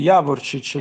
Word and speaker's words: Javorcic: Jarovic, giusto? se pronuncia in questo modo. – Sì, sì, Javorcic, il mister lo Javorcic.